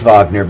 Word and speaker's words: Wagner: spelled Wagner, W-A-G-N-E-R, but it's actually pronounced Wagner because he's German.